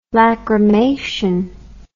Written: lacrymation

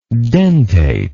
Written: dentately

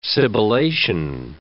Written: sibilation

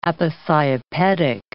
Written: aposiopetic

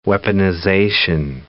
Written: weaponization

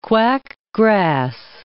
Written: quack grass

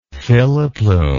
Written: filoplume

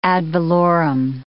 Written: ad valorem

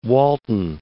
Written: Walton

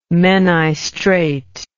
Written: menai strait

